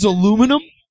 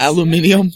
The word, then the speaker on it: aluminum: Number 10 is aluminum. – No, that's how that word is pronounced. I'm going to fight all of these. – Aluminium? – No, yeah. Wrong!